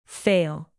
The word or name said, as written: fail